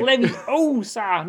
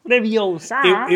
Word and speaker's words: Leviosa: It's like, Leviosa, not Leviosa.